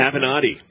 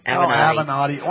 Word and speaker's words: Avenatti: But we're going to talk about Michael Avanti. Is that how you say it? – Avenatti. – Avenatti.